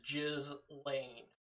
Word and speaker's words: Ghislaine: I Gillian? – I'm almost 100% certain the proper pronunciation is Ghislaine.